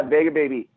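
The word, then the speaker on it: Vega Baja: And it looks like the next spot that you end up is at, is it pronounced Vega Baby? – Yeah, Vega Baby.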